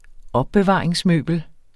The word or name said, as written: opbevaringsmøbel